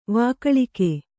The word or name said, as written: ವಿಘಳಿಗೆ